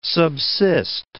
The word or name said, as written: subsist